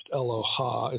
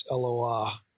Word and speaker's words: Eloah: It's not pronounced Eloha, it's Eloah.